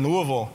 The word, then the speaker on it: Louisville: I have a friend who actually says the way you pronounce Louisville, you have to say it like you've got a Louisville Slugger stuck in your mouth. You know, Louisville, you kind of give up on all those vowels.